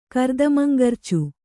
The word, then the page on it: ಕರ್ದಮಂಗರ್ಚು: Kannada